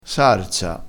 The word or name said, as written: sarsa